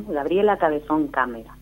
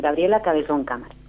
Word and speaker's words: Gabriela Cabezón Cámara: Gabriela Cabezón Cámara. – Ajá. – Cámara. Lo dije mal. Gabriela Cabezón Cámara.